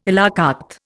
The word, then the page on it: à la carte: Indian